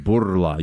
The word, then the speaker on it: Bourla: He prefers pronunciation Bourla. You have to roll the R.